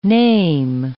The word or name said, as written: name